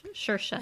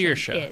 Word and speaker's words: Saoirse: It's spelled S-A-O-I-R-S-E. – Yeah, and I'm thinking, how not in the world did you get Shersha from that?